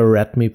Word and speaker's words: Redmi: Aber man hat ja Redmi schon mal als Untermarke, die man ja auch versucht auf dem Markt zu etablieren und Redmi spricht sich ja schon mal. Ich finde, das äh, klingt schon irgendwie amerikanisch. Can you give me a rat me, please? – Oh, yes.